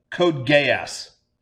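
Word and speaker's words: Code Geass: How is this spelled? – Yes. G-E-A-S-S. Geese? Code gay ass. Yes. Code gay ass. That's how you say it?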